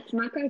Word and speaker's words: maca: I'm actually having my inulin coffee right now with cinnamon and a little maca. Is that how you say it? – Yes, maca is great.